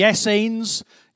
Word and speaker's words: Essenes: We had the Essenes. Yes, I did have to Google how to pronounce that. It told me it rhymes with beans.